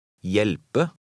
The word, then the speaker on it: Hjelpe: When a word begins with an HJ combination, the H is silent. Try to read number 11. – Hjelpe.